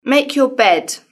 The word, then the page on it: make your bed: British English